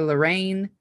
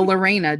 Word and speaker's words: Lorena: You might hear me call her Lorinda. I've got some issues today. – She's been saying her name wrong for like a month. – I have. But Lorena, just so everybody knows, is the correct term here.